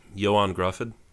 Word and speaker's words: Ioan Gruffudd: what is how do you pronounce his name? Is it Owen or Ian Griffod or however? – Oh, the Welsh guy. I think it's uh Ioan Gruffudd.